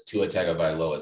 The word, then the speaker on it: Tua Tagovailoa: Yeah, they don't have a Jalen Hurts, Tua Tagovailoa situation, whereas like second quarter, halftime.